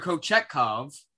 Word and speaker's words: Kochetkov: The other, Pyotr Kochetkov. Am I saying it right? – You're saying it right. – Whatever. It's close enough. Anyway, 8-0-1